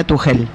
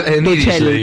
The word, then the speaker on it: Tuchel: Ayer aprendí, vinieron los chicos de la redacción de deportes del Destape anoche a Batalla y aprendí que se pronuncia Tuchel. – Tuchel. – Yo creí que era Tuchel, pero bueno.